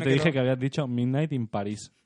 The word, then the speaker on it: Midnight in Paris: Pero al final es como cuando le- al final es como un poco lo que le dijeron el otro día, que Midnight in Paris, ahora lo he dicho bien, Midnight in Paris se queda en tres, cuatro escenas interesantes.